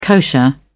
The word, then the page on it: kosher: British English